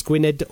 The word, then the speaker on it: Gwynedd: Now, Gwynedd... Well, Aberdaron, let's spell that one first. A-B-E-R-D-A-R-O-N. Now, Gwynedd is spelled G-W-N-Y-D-D. In Welsh, the double D can give you a TH sound, so it's Gwynedd or Gwynedd.